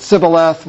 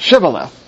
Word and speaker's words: Shibboleth: And the Ephraimites pronounce it Sibboleth, whereas the Gileadites pronounce it Shibboleth.